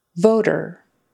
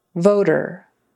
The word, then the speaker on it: voter: Voter. Voter. So long O, O, and then sort of a tap T, D sound in the middle. Voter.